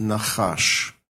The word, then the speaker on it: nachash: And the Hebrew word is pronounced nachash.